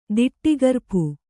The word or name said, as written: ದಿಟ್ಟಿಗರ್ಪು